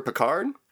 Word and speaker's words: Pickard: Is that how you say his name, or Picard? Like the captain, yeah.